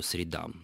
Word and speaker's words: средам: Так вот, вы же никогда не скажете, согласитесь, ударить кого-то по губам. Именно поэтому нужно говорить по средам.